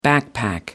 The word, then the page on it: backpack: American English